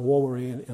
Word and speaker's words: vovere: The word there in the Latin, de vovere, de means down or away, and vovere is, it'd be pronounced vovere in Latin, means vow.